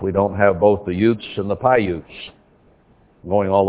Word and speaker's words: Uz: Here we find Utes, or Uz, but would be pronounced Utes, and we have the Utes here in Utah and other parts of the Southwest.